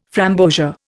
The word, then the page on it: framboesia: Indian